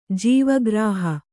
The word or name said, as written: ಜೀವಗ್ರಾಹ